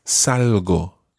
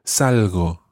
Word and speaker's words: salgo: It starts with salgo, salgo. – Salgo. – Yeah, it's one of those soft G sounds, so it's quite a salgo.